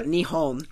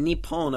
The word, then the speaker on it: Nihon: So Nippon. – No, Nihon. – Nihon. – And then Nippon, apparently, is the way to use it when you're talking in the sense of like a patriotic sense, I guess.